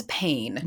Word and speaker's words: Payne: And the other one is Pain. – Never was there a more aptly titled sitcom than Pain. It's spelled P-A-Y-N-E.